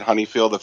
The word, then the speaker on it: Honeyfield: It's an American spelling of a German name. And, I don't think there's a right way to say it, to be honest with you. I've always said Honeyfield. Honeyfields always say Honeyfields.